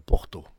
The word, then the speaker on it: porta: Quand vous avez un mot qui finit par la lettre A, il se prononce O. Et si vous voyez écrit porta, il faut dire porto. – Mm. – Porto, non porto.